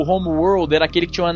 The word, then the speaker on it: Homeworld: Por favor, esse nobre, a pronúncia certa de Homeworld. – Homeworld. – Isso, muito bom.